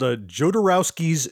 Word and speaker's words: Jodorowsky's: And it's called Jodorowsky's Dune.